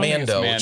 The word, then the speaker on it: Mando: Is it he's Mondo just like, or Mando? Oh, am I saying it wrong? – Mando. Short for Mandalorian.